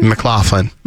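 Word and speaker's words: MacLachlan: Yeah, I mean, you almost said his name right, but yeah. – McClanahan? – McLaughlin. – McLaughlin. – Yeah. – I've only ever seen it written down.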